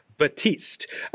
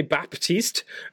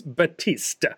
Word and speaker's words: Baptiste: There's no P. You don't pronounce the P. It's Batiste.